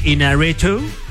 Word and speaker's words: Iñárritu: Iñárritu. – Yeah. – No sé si lo estoy pronunciando bien. – No, muy bien.